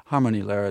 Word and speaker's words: Harmonielehre: And I'm thinking of Harmonielehre. Am I pronouncing that right? – Harmonielehre.